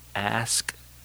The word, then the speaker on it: ask: Well, I say fifth grade, you know, I learned how to use the word ask someone. You didn't ax them anymore.